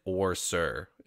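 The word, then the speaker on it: OreSeur: I know it's one, like one word in the book, but they specifically put it into two separate like syllables, so it's definitely like Orsir in the audiobook, if that helps you.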